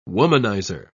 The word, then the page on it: womanizer: British English